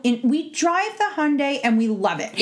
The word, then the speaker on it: Hyundai: Tricky. Silent I. – Hyundai. – No, Hyundai. – Hyundai. – There you go. – Like Sunday.